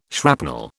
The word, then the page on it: shrapnel: British English